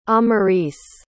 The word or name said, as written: amaris